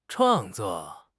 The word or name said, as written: 创作